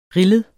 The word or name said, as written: RILLEDE